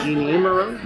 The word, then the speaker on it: Jeanne Moreau: Is it Jeanne Moreau? – Jeanne Moreau.